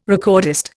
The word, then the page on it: recordist: Indian